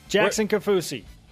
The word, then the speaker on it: Jackson Kaufusi: Jackson Kaufusi. – Where's Isaiah, by the way? Tonga. – You said it correctly.